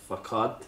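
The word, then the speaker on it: façade: simple as social media is a facade.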